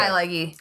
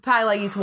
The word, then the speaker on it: Pileggi: Appropriately, that film was written by Nora Ephron, who is Nicholas Pileggi. That's how you say it. – Okay. – Pileggi. – Gotcha. – Pileggi's wife.